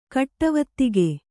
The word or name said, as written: ಕಟ್ಟವತ್ತಿಗೆ